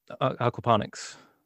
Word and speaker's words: aquaponics: I always say Aquapondrix because I see the D. And they're like, the D is silent. I'm like, no, it's not. – There isn't a D.